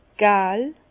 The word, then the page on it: gäl: Bas-Rhin; Herrlisheim